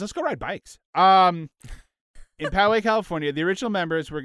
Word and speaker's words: Poway: Poway. That's how you say it. Poway, California.